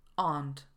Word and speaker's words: aren't: Zacznijmy od formy aren't, wymawiane aren't. Aren't we nice? Nie ma czegoś takiego jak aren't.